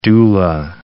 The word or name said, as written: doula